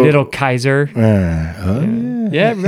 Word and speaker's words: Caesar: There is no soft C in Latin. There was no Caesar. – Oh. – It's where we derive the word Kaiser, like the German Kaiser.